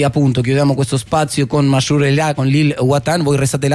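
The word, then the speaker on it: Mashrou' Leila: Di Mashrou Leila. – Mashrou Leila. – Mashrou Leila, sì. L'ho detto bene. Sì.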